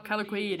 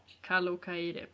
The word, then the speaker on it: Kalokairi: Kalokairi. – Aj då. – Kalo Kairi. Alltså uttal, herregud. Men ja, Kalo Kairi i alla fall.